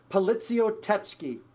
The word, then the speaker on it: poliziotteschi: That's kind of the general term, but in Italian, it's poliziotteschi.